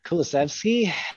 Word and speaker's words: Kulusevski: Put me on the spot, Dave, but yeah, I'd go with Kulusevski and Bentaker.